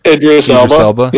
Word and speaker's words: Idris Elba: I can never say his name right, Idris Elba? – Idris Elba.